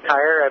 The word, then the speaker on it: Tyre: I was reading about the king of Tyre, I believe you pronounce it that way.